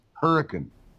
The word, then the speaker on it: Hurricane: The town Hurricane is pronounced Hurricane. – Hurricane, yeah.